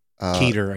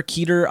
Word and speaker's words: Keter: Now, for our purposes, however, you'll need to know about safe class anomalies, Euclid, Keter. – Keter, I think is... – Keter or Keter. Okay. I've heard different narrators pronounce it different ways. I think Keter sounds better.